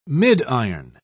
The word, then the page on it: midiron: British English